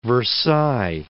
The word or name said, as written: Versailles